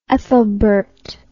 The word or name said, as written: Aethelbert